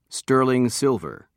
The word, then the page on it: sterling silver: British English